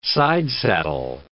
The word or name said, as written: sidesaddle